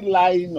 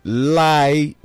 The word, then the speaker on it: Lai: Is it lying or what? What's the pronunciation? – L-A-I. Lai Muhammad. – I didn't tell you spread information. What was the pronunciation? Lying Muhammad, Abi? – No, Lai Muhammad.